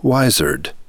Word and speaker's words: wizard: A person who is wise is a wizard. Since the S is pronounced as a Z, it came to be spelled with a Z. Wizard.